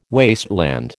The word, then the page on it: wasteland: American English